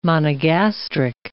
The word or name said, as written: monogastric